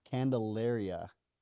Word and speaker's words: Candelaria: Did I say it right? – No, not at all. – Well then intro yourself, you know what I'm saying? Go ahead. How do you say your last name? – Candelaria.